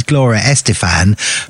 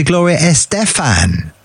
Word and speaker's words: Estefan: I love it, but there's a bit of confusion over how we say her name now, because you say it how I've always said it for 100 years, which is Gloria Estefan, but now she's changed to Gloria Estefan, I think.